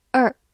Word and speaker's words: her: Her，H-E-R，her，这个词有很多种发音，可以发短音 her，或者长音 her，或者在句子读快的时候，前面的 h 也可以不发音，读作 er 或者 er 也是可以的。